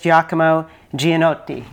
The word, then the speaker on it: Giacomo Giannotti: And that is Giacomo Giannotti. Is that pronounced correctly? – Yes, perfectly.